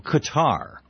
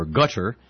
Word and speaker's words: Qatar: I'm informed by the folks in our chat room that Tommy Franks was in Qatar, or gutter, depending on how you where you're from in the world and how you pronounce it, or Qatar.